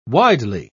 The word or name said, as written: widely